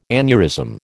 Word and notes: aneurysm: American English

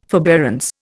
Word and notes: forbearance: Indian

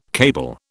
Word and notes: cable: British English

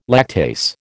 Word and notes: lactase: American English

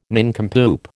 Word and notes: nincompoop: American English

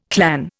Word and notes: clan: Indian

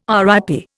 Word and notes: RIP: Indian

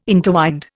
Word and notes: interwind: Indian

